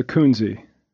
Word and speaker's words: Koense: It's Koense.